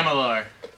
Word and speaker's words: Amalur: Amalur.